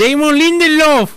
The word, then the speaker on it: Damon Lindelof: Damon Lindelof.